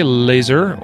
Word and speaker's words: LASR: We say laser or LASR because it stands for Laser Activated Shot Reporter, and I'm a huge fan of the Laser App software, as is Jacob.